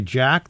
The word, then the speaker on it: JATK: This is a new song by Jack. That's pronounced, that's spelled, I should say, J-A-T-K.